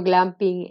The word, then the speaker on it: glamping: Explícanos qué es eso de glamping.